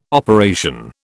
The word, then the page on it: operation: British English